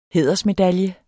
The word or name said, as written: hædersmedalje